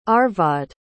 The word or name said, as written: Arvad